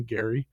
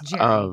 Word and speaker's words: Gerry: It's G-E-R-R-Y, so I don't know if it's Jerry or Gary. I'm saying Gary. – It's Jerry.